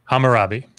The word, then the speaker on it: Hammurabi: Hammurabi. You got Hammurabi.